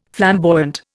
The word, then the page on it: flamboyant: Indian